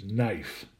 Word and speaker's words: knife: So what my peg word for number 28, based on those consonant sounds, is the word knife. Okay, now, of course, knife is spelled K-N-I-F-E, but again, remember, we're only interested in the sounds. So that first K is silent, so that is irrelevant in our system here.